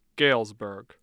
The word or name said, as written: Galesburg